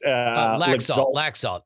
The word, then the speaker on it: Laxalt: It's Laxalt.